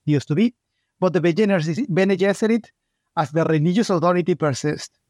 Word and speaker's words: Bene Gesserit: It's well known that Frank Herbert chose the name Bene Gesserit to rhyme with Jesuit.